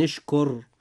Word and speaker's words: اشكر: نجد قول الحق سبحانه وتعالى أن اشكر، كلمة اشكر حرف الراء جاء في هذه الكلمة ساكنا، وجاء ما قبله مضموما، وإذا جاء حرف الراء ساكنا وما قبله مضموما فيجب أن نفخم هذه الراء عند نطقنا لها في هذه الكلمة هكذا.